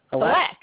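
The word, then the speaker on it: Halák: Halak? – Halak.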